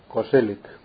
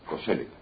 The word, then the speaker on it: Koselleck: No, es que recientemente, leyendo a Koselleck, no recuerdo ahora, ¿cómo se dice? ¿Koselleck o Koselleck? ¿O Koselleck? Koselleck. – Koselleck.